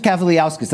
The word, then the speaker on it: Kavaliauskas: He just Kavaliauskas. That's my Lithuanian brother. All right? – Yes. – Come on. – Kavaliauskas,